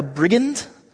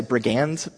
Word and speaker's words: brigand: Well, really what probably is going on is the Romans typically only crucified you if you were either a brigand. In my heart, I want to say brigand, but I looked it up on Google. I pressed the button, the YouTube, for correct pronunciation. Apparently it's brigand.